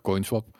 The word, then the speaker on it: CoinSwap: De, wat is nou, CoinSwap? Ja. Zeg ik het goed? Ja, CoinSwap.